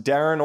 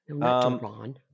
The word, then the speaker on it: Deron: All right, 37-year-old, always mispronounced, Darren or Deron Williams. – Deron.